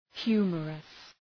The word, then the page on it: humorous: ‘hju:mərəs